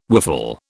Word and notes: whiffle: British English

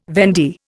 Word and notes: vendee: Indian

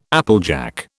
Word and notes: applejack: British English

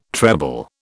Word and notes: treble: British English

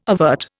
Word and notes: avert: Indian

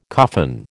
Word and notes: coffin: American English

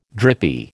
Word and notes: drippy: American English

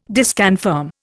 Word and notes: disconfirm: Indian

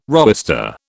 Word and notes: roister: British English

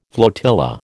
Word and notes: flotilla: American English

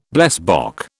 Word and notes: blesbok: British English

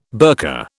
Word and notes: burka: British English

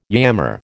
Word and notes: yammer: American English

